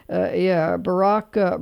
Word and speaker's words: Birach: And Barrock Broadcasting, and it's spelled B-I-R-A-C-H.